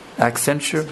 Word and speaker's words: Accenture: Accenture?